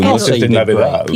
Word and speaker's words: Noches de Navidad: The Noches de Navidad. – Ooh, Noches de Navidad.